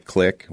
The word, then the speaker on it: Qlik: We get a lot of opportunities through some of our software partner vendors, most notably companies like Qlik, which is spelled Q-L-I-K, and DataRobot.